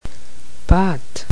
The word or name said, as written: pate